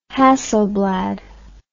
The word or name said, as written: hasselblad